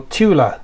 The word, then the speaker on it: Thule: Or Thule, depending on where you come from. – Neil is the linguist. The two of us. – I think we do pronounce it Thule, but because there are Thule products. There's a company called Thule.